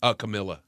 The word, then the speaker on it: Camilla: Camilla.